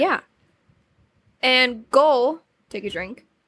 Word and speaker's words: Gohl: No, it's Ghoul. Or no, Gohl. Sorry, Gohl. – Gohl. Billy Gohl. Oh, well. The sheep fan. – Yeah. So his name is spelled G-O-H-L.